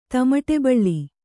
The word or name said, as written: ತಮಟೆಬಳ್ಳಿ